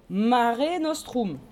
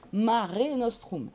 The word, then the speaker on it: Mare Nostrum: Et comment, rappelez-moi, ils appellent cette mer Méditerranée Mare Nostrum. Notre mer. Mare Nostrum. M-A-R-E, plus loin, N-O-S-T-R-U-M. Hein, le U en latin, il se prononce OU. Et le E, on prononce toutes les lettres. Mare Nostrum. M-A-R-E, plus loin, Nostrum, N-O-S-T-R-U-M.